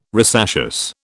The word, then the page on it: rosaceous: British English